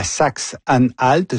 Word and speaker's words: Saxe-Anhalt: Donc, élection importante demain dans la Saxe-Anhalt. Je prononce bien, oui. – Parfait, ouais, parfait.